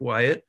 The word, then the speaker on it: Wyett: But my name is Patrick Wyett, and you spell that W Y E T T.